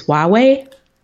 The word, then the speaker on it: Huawei: I can over pronounce this, Huawei.